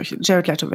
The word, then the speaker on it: Jared Leto: Und das, ich kann mich an einen Moment erinnern, da hatten wir Jared Leto, Jared Leto wird er ausgesprochen, war zu Gast.